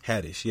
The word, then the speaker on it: Haddish: Haddish.